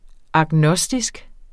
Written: agnostisk

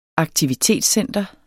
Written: AKTIVITETSCENTER

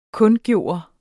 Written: kundgøre

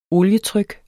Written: olietryk

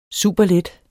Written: superlet

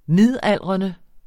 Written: Midaldrende

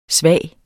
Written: svag

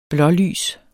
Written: blålys

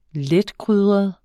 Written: letkrydret

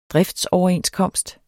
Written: driftsoverenskomst

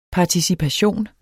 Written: participation

